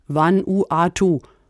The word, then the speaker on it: Vanuatu: zum Beispiel in Vanuatu.